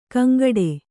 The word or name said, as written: ಗೊಂಗಟೆ